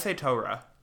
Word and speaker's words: Torah: We did get extra credit one time because our one friend memorized like five passages in the Torah from Torah. That's how you say it. Torah. – Because that's like the if you were reading it in Hebrew, it would say Torah.